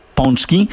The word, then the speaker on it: pączki: Większość, choć Amerykanie mówią czasami paczki day, większość Polaków wie, że to się wymawia pączki.